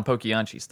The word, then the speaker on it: Poquianchis: The story of the Las Poquianchis. And we're going to butcher a lot of Hispanic names and Mexican names and territories and just get ready for that. – But we're pretty sure on Poquianchis, though.